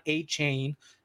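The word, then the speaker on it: Achane: They're going to get Devon A-Chain, like he likes to be pronounced, is coming back in a few weeks.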